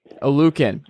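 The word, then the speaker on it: Oluokun: Foy Olukon, is that how you pronounce it? – Olukon. – Yep.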